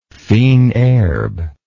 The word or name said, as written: fines herbes